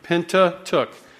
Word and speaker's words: Pentateuch: Pentateuch. You could think penta means five. A pentagon is five-sided. Tuch rhymes with book.